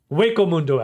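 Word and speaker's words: Hueco Mundo: It is Hectomundo Sneak Entry Arc. Waco Mundo, actually. That's how they say it. I don't know why I was thinking of Hector, but yes.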